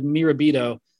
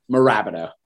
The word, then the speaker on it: Mirabito: And I realized I mispronounced the name of the stadium when I started this segment. I've been to Central New York. I've seen those convenience stores. I still said Mirabito, but it is Mirabito.